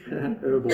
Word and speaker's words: herbal: Basil is an herbal, well, I can say herbal, you'll understand that in America they don't pronounce the H, herbal, antibiotic and antiseptic, carminative and appetizer that has a special affinity for the stomach.